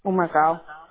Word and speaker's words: Humacao: H-U-M-A-C-A-O. – H-U-M as in Michael? – Yep. – A-C-A-O? – Yep. – How do you pronounce it? – Humacao, Puerto Rico. – I like it. Humacao, right? – Yeah. – A-E-O-U.